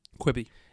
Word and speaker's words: Quibi: But also, if Quibi, or is that how you pronounce it? Quibi? – Quibi.